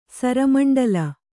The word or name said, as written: ಸರಮಂಡಲ